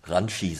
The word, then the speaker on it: Ranchi: Ranchi sagen es die Inder.